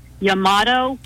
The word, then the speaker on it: Yamato: He was also in the task group that finally found and sunk the, I'm hoping I'm pronouncing this right, Yamato carrier. – Yes.